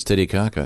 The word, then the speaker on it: Titicaca: Okay, the pronouncer would indicate it's Titicaca.